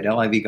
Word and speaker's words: LIV: One to watch for the future as the LIV, and I'm saying that right, LIV Golf Tour starting in London this week, backed by Saudi money.